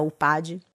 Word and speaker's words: Upadhi: Então, se você digitar lá na barra de busca do YouTube, Flávia Melissa Upad, se pronuncia U-P-A-D-H-I, você vai achar essa gravação.